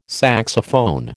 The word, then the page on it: saxophone: American English